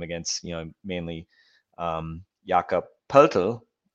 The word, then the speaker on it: Poeltl: And I went and watched a German interview, well, Austrian TV interview, and that's how they pronounce it, Poeltl.